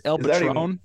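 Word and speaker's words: El Patrón: El Patreon. – I think I butchered that. – I believe it's El Patron.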